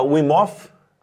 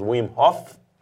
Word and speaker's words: Wim Hof: Em Portugal se fala Wim Hof, no Brasil nós pronunciamos o H, então falaremos Wim Hof, Exato.